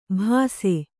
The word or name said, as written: ಭಾಸೆ